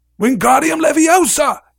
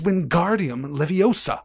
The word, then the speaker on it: Wingardium Leviosa: Wingardium Leviosa! he shouted, waving his long arms like a windmill. You're saying it wrong, Harry heard Hermione slap, snap. It's Wingardium Leviosa. Make the gar nice and long.